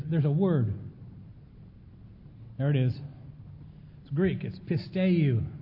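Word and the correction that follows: Pisteuo (πιστεύω): Pisteuo. I'll get it right. Pisteuo is how it's pronounced.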